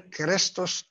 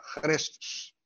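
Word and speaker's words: خريستوس (Χριστός): كريست أو كريست جاي من كريستوس أو خريستوس، في اليونانية مفيش كاف تتنطق، خريستوس معناها المسيح.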